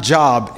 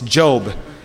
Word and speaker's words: Job: The book right before, it's not Job, it's Job. I know it's spelled like Job. It should have an E at the end, but it's pronounced Job.